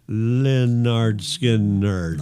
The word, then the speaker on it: Lynyrd Skynyrd: Lynyrd Skynyrd released their debut album pronounced Lynyrd Skynyrd.